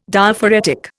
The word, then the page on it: Diaphoretic: Indian